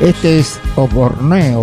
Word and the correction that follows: Osborne: Bertino Osborne. – Muy bien, gracias. Gracias. ¿Cómo lo pronuncia? A ver, de vuelta. – Bertino Osborne. Cuando empecé a escuchar Americano, pensé con estos temas.